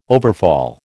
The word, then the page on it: overfall: American English